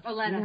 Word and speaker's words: Olenna: Olenna? Olenna, yeah. Sorry. I don't know why I keep thinking. I always want to put an extra syllable in her damn name.